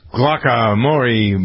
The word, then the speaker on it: guacamole: First, though, something much more important going on for those of you who are guacamole.